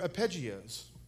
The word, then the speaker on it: arpeggio: It's also learning your arpeggios.